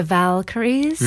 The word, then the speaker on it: Valkyries: But mine are tell me if I'm pronouncing it right, the Valkyries.